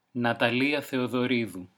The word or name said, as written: Natalia Theodoridou